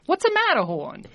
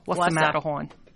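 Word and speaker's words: Whassa Matterhorn: And she says, you have to say it like you're in Jersey Boys. What's a Matterhorn? – Got it. Got it. – And so it's spelled W-H-A-S-S-A, Matterhorn. – Mm-hmm. What's a Matterhorn?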